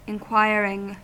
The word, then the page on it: inquiring: American English